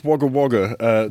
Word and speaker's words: Wagga Wagga: The best thing to come out of Wagga Wagga, New South Wales, since Dame Edna Everidge. She actually is from Wagga Wagga, isn't she? – It is pronounced Wagga Wagga. To correct you somewhat.